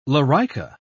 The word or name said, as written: lorica